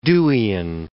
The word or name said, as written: Deweyan